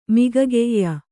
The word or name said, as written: ಮಿಗಗೈಯ